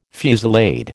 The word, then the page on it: fusillade: American English